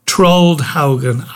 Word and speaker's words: Trollhättan: That's how you pronounce it. I seem to have been mispronouncing it all this time. Trollhättan, I believe it is.